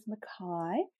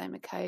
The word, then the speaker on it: McKay: Yeah, it is Scottish. My grandparents are Scottish, so it is McKay. And that's how the Scots pronounce it.